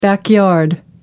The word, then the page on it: backyard: American English